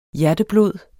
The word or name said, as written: hjerteblod